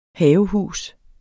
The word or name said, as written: havehus